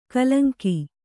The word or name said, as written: ಕಲಂಕಿ